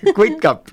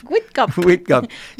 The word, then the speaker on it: Whitcup: Whitcup. Whitcup. Se escribe Whitcup, pero pues la U ahí suena abierta. A.